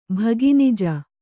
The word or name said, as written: ಭಗಿನಿಜ